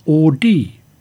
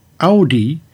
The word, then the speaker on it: Audi: The accepted Latin pronunciation is Audi, but Audi is more natural to German speakers.